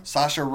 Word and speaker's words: Saoirse: So Saoirse Ronan. – Saoirse Ronan is her usual Oscar nominated self.